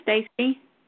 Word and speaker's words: Stacey: My name is Stacy. – How do you spell it? – S-T-A-C-E-Y.